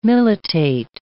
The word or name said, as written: militate